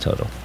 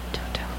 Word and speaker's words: Toto: So, but it's Toto. It's pronounced Toto. – Toto.